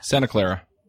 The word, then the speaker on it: Santa Clara: Santa Clara.